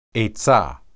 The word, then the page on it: etsah: Hebrew